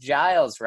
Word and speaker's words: Giles: It's always mispronounced, but it's pronounced Giles. Rhymes with Miles.